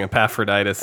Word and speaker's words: Epaphroditus: I just want to congratulate you on pronouncing Epaphroditus so like clearly.